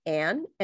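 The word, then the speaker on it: An: So you can follow me at makeup by Alice Ann.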